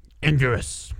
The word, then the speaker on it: Anguirus: Anguirus.